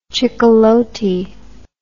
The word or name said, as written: chicalote